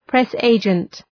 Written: press agent

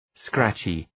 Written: scratchy

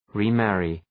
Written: remarry

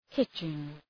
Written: hitching

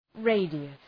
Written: radius

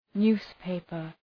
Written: newspaper

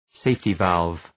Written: safety valve

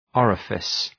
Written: orifice

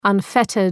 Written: unfettered

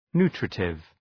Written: nutritive

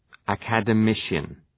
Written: academician

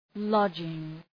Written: lodging

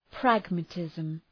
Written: pragmatism

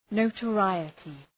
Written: notoriety

